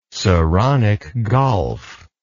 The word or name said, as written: saronic gulf